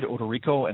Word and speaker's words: Odorico: It's Ted Odorico, and my last name is spelled O-D-O-R-I-C-O.